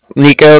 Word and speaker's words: Niko: My name's Nico.